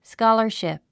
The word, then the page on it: scholarship: skɑ́lə r ʃìp; スカラァシプ